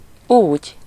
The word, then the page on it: så: /soː/